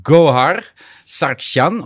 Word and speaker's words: Gohar Sargsyan: And sitting next to you is my my dear colleague uh, Gohar Sargsyan.